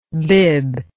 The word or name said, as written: bibb